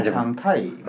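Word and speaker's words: 신창타이 (新常态): 신창타이. – 신창타이. – 근데 이게 중요해. 이게 신창타이. – 신창타이. – 맞네. – 다시 한 번 발음. – 신창타이. – 신창타이. 아 타이가 세게 발음해야 돼 그걸.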